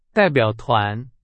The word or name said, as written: 代表团